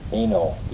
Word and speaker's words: Eino: Eino, E-I-N-O.